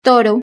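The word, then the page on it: toro: European Spanish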